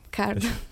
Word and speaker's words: KARD: Kárd. Kárd, oké. Okay. Igen. Ö, hát mint, mint kártya, és igen, és egy K betűvel helyettesítik a C-t, mert oh.